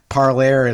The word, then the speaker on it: Parler: Well, I think I always thought it's pronounced parlor, but maybe uh, yeah, knows? yeah, yeah. No, probably it is. Uh, uh I, I, I've heard Jonathan Zittrain use uh, uh, and since I was in the parlay school, Parlaire is a way is a, is a good halfway house for me.